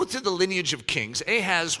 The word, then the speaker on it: Ahaz: But his name is Ahaz. Well, that's how we say it. His name is technically Ahaz, but that's how we pronounce it in the English in our transliteration.